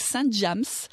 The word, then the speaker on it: Saint James: Donc, je travaille avec une marque qui est une marque française très reconnue, de référence, qui s'appelle la marque Saint James. Ce n'est pas une erreur de prononciation parce qu'en français, on dit Saint James. – Pour Saint James. – Et en anglais, Saint James.